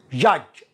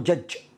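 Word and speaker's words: judge: جاج جاج وليس الدي هنا لا ينطق لا تقول جج جاج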